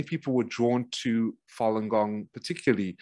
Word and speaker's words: Falun Gong: So when Falun Gong particularly, and it's pronounced Falun Gong, G-O-N-G, Falun Gong, became the largest spiritual movement in China.